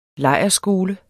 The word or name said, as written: lejrskole